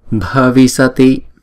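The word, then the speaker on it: bhavissati: Bhavisati. Riêng chữ bha này chúng ta phải đọc âm bậc là bha, bhavisati.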